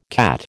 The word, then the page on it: CAT: American English